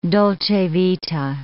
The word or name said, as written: dolce vita